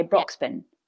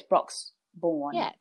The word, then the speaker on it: Broxbourne: I always say it how you say Brisbane. Right. If you say Brisbane, you'd say Broxbourne, but it's not. It's Broxbourne, I believe. So I've been saying it wrong.